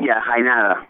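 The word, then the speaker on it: Jaenada: There she meets a younger man played by Oscar Janeta. Janeta? Am I saying that right? – Yeah, Janeta. Yeah. – All right, Janeta. – You know, Spanish, yeah.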